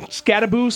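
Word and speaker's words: Skattebo: Cameron Scadaboo, spelled B-O, but pronounced boo.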